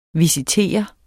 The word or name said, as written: visitere